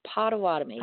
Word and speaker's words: Potawatomi: And I'm not sure how to say the last one, Potawatomi. – Potawatomi. Okay.